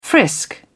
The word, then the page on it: frisk: British English